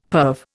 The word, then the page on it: perve: Indian